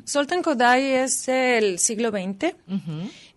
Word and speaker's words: Kodály: Su labor musical fue muy, muy importante a nivel mundial porque él desarrolló el método Kodály, que yo creo que aquí se conoce como método Kodály porque se escribe de esa manera. Y es un método de enseñanza musical a los niños y uh-huh. es muy, muy común, se extendió por todo el mundo y aquí creo que también hay algún instituto Kodály o Kodály, sería la forma de pronunciarlo.